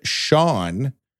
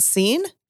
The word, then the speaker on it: Sean: The word Sean, but spelled S-E-A-N, is the most mispronounced baby name in the world. – Do people call it Scene? – Yeah.